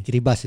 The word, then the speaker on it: Kiribati: Ada Kiribati. – Kiribas itu bacanya.